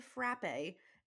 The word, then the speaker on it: frappe: It's spelled frappe, F-R-A-P-P-E. But it is pronounced frap, and I will get into why.